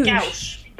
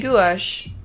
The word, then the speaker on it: gouache: I always say gouache. – Gouache. – Like a single syllable. – I always say gouache. Gouache. – You say it almost like two syllables and I say it with like one syllable.